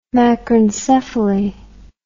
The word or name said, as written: macrencephaly